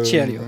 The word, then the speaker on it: Cirio: Vous le prononcez mieux que moi. – Cirio, si.